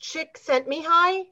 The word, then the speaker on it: Csikszentmihalyi: Mikhail, I think it's pronounced Csikszentmihalyi. – Csikszentmihalyi, that's it.